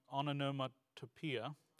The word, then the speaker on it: onomatopoeia: The word itself is a kind of a, I always have trouble pronouncing this word, onomatopoeia.